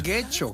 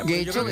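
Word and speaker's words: Getxo: En Guecho, que nunca sé cómo se pronuncia. – ¿Guecho? – Guecho, yo creo que lo pronunció bien.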